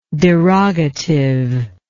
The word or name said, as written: derogatively